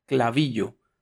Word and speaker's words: Klaviyo: que se llama Klaviyo. Se escribe K-L-A-V-I-Y de yuca o clavillo pueden ver más sobre esta plataforma.